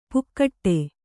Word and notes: ಪುಕ್ಕಟ್ಟೆ: Kannada

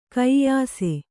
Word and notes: ಕೈಯಾಸೆ: kaiyāse

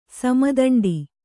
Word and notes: ಸಮದಂಡಿ: sama daṇḍi

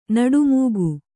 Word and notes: ನಡುಮೂಗು: Kannada

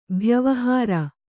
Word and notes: ಭ್ಯವಹಾರ: bhyavahāra

